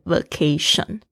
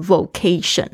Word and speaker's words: vacation: 大家特别注意一下这个发音。我以前刚学英文的时候，我也发错，我会讲成 vacation。然后我现在才发现，我的学生会会说成 vocation，然后会拼成 v o c a t i o n，那个就变成一个天职，那就不对了哦。